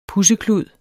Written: pudseklud

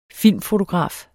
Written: filmfotograf

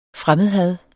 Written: fremmedhad

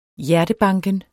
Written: hjertebanken

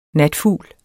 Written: natfugl

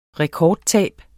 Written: rekordtab

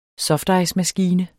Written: softicemaskine

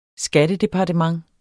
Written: skattedepartement

